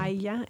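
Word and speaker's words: Haya: Aya. It's H-A-Y-A. Aya.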